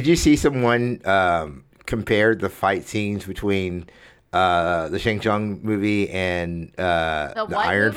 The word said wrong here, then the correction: Shang-Chi: So, like, the main actor for Shang-Chi, am I saying that right? – There it is.